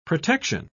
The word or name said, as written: protection